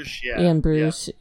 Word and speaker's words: In Bruges: I mean, Bank Job is a great film, and I'm going to butcher it, in Burgess with Colin Farrell. – Ian Bruges.